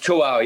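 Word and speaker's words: tawâw: You know, you've got your cookbook, Tawau. Is that, am I pronouncing that properly? – It's Tawau, yeah.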